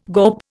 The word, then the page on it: gawp: Indian